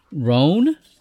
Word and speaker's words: Rohn: The captain is a Vulcan named Captain Rohn. That's how we're going to pronounce it, R-O-H-N.